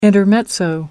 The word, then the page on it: intermezzo: American English